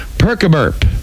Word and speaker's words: percuburp: Perk-a-burp. It's spelled P-E-R-C-U-B-U-R-P. Perk-a-burp.